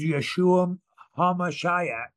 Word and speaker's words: Yeshua HaMashiach: And the Messiah's name here on earth, the name higher, most higher than any other name, is Yeshua HaMashiach, is how you say it.